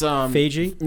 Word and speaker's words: Feige: Feige. – I think he's the guy. He's the guy running the Marvel Cinematic Universe. I think. – Oh, it's Feige. – No, it's Feige.